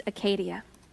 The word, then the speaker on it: acedia: A-C-E-D-I-A, pronounced Acadia.